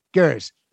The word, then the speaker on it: Gers: Gers.